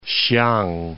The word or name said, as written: Hsiang